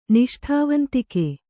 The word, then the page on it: ನಿಷ್ಠಾವಂತಿಕೆ: kannada